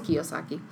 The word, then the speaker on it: Kiyosaki: I think it's by I never know how to pronounce his last name, Robert Kiyosaki or Kiyosaki. – Okay, mm-hmm. It is Kiyosaki.